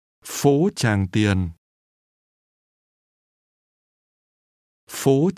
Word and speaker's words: Phố: Phố. – This is pronounced with a high, rising tone.